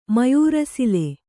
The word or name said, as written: ಮಯೂರಸಿಲೆ